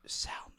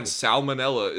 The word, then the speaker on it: salmon: Fine, salmonella is fine, but and I may have pronounced it salmonella in the past, but it's salmon, you degenerate.